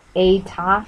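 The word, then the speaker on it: A-ta (爱他): The symbol is pronounced A-ta, A-I-T-A, and the baby's parents noted its similarity to a phrase that means love him.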